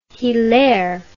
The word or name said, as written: hilaire